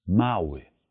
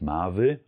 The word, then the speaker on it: mały: Spreche ich hingegen von etwas Kleinem, dann sage ich auf Polnisch mały, mały, mały. Und hier auch bitte nicht Marvel, also wir sprechen wieder kein W-Laut, sondern ein Maui, Maui, Maui.